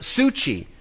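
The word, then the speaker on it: suchi (ψυχή): Jesus could have also used the word, Greek word for life, which means, which is pronounced suchi. Suchi, it's where we get the word psychology from.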